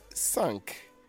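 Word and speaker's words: cinq: That's Q-U-A-T-R-E, 4. Everybody say 4. And 5 is what? Sunk. 5 is sunk. C-I-N-Q. C-I-N-Q, sunk. And 6 is seas, S-I-X. S-I-X, that's C's, and that is 6, all right?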